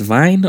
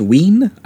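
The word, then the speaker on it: Wiene: directed by Robert Vine, or also Robert Wien. I've heard a lot of the German critics who I was watching interviews, that's how they pronounced it.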